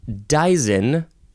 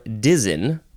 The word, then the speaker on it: dizen: It is dizen or dizen. Yeah, it's just spelled like it sounds. D-I-Z-E-N.